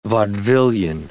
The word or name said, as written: vaudevillian